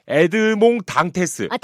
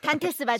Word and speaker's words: 에드몽 당테스: 아, 발음을 잘해야 돼요. 프랑스어입니다. 에드몽 당테스. – 단테스 맞죠? – 프랑스어 전공 아니세요?